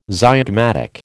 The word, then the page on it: zygomatic: American English